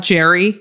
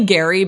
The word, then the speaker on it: Gerry: It's not Jerry. – No, Gary. It's definitely Gary, but it's spelled G-E-R-R-Y.